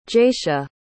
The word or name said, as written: jaysha